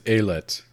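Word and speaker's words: ailette: It says Aliette in the French pronunciation. – That makes me wonder, because her nickname that people use is A-I-L.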